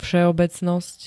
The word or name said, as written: vseobecnost